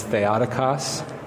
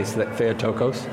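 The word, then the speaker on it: Theotokos (Θεοτόκος): I am talking about the Theotokos, or as my Greek professor said, the correct pronunciation is Theotokos. But yeah. Everybody pronounces it wrong, so we say Theotokos.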